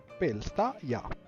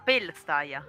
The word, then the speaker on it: Pelstaya: Mi dici come si scrive Plestaia, Pelstaia, che ogni volta lo sbaglio? – P-E-L-S-T-A-Y-A. – P-P-L-E? – S-T-A. – Sì, Y-A. Sì. Plestaia. – Pelstaia. – Ah, Pelstaia.